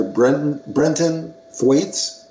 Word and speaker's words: Brenton Thwaites: and he will be played by actor Brenton Thwaites. Is that how you pronounce it? I'm pretty sure. – Sounds right to me, yeah.